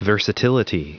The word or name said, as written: versatility